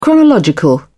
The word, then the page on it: chronological: British English